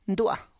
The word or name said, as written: ndua